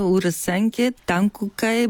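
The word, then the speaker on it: Урасенке Танкокай: Първо, правилно ли е произношението и също така какво означава името на сдружението? – Ами, правилно е Урасанкия Тънкокай България.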